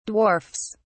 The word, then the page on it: dwarfs: British English